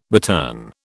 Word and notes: bittern: British English

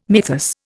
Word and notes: mythus: Indian

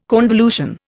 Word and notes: convolution: Indian

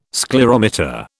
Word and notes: sclerometer: British English

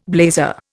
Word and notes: blazer: Indian

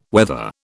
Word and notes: weather: British English

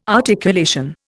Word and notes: articulation: Indian

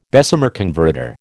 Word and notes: Bessemer converter: American English

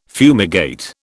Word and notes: fumigate: British English

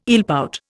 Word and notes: eelpout: Indian

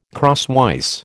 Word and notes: crosswise: American English

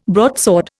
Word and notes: broadsword: Indian

